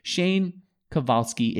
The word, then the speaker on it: Shane Kovalsky: Shane Kovalsky is the founder.